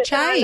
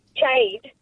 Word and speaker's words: Chade: It's C-H-A-D-E. As in Chad. With the Chade. – Silent E. Yeah, not Chade. Chad.